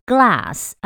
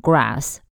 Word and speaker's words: glass: glass 要小心它的发音哦，是了的声音 glass，如果你卷舌卷太里面会变成 grass 草，地上的草，所以你要小心是念 glass。